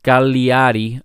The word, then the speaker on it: Cagliari: It's actually pronounced Cagliari are 11th with 41.